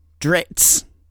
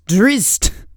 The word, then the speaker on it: Drizzt: It's not, as I keep saying, Dritz. It's Drizzt.